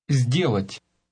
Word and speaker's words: сделать: Сделать. Сделать. – Notice the z sound at the beginning.